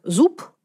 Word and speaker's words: зуб: То есть такие слова, как «зуб», произносятся со звуком «п» на конце, в именительном падеже единственного числа.